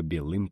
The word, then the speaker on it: белым: Помнишь, ты когда-то писал стих, да так и не дописал?» Распустила черные волосы, да по белым плечам. Продекламировал я, задирая палец. В слове «белым» ударение на «ы».